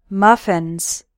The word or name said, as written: muffins